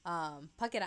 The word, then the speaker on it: Phuket: And so, when Juno calls Leah and tells her, like, she's pregnant, she ends up saying, like, Phuket Island in Thailand, which is actually a real place, but it's literally pronounced like Fuck It Island.